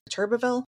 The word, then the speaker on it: Turbeville: He is at Turbeville. Did I say that right? – Correct.